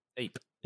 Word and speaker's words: Ypres: He was present at the First Battle of Ypres. Am I saying that right? Deep. Mm. Eep. – Eep. What do I? – Yep.